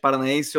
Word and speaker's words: Paranaense: Acho que é abaixo do Atlético, do Atlético Paranense que eu gravei Esqueci que quando eu tô falando não tem a pronúncia do H ali no meio, é. ah, tá Abaixo do Atlético Paranaense ou não, mas acho que é ali abaixo do Atlético eu fecho contigo.